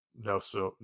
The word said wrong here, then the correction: Nwosu: Nwosu. There we go.